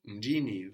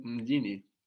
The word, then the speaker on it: mjini: So we add on this ni and then the accent moves as well when we add on a syllable. So we have the stress on Mji and then Mjini.